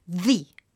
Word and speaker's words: the: E tra l'altro notate la pronuncia di the quando vuole essere proprio così enfatico per dire il più importante, quindi non the most important, ma è come se si isolasse la parola e quindi la si pronuncia con quella E finale, leggendo quella E finale che in generale non si legge, quindi the most important cultural object of humanity.